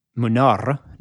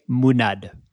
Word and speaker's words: Munar: And then Munar, which you'd think, okay, how would you pronounce Munar? Munar, no, that's the Spanish way. Catalan, the R is a D. So Gaume Munad.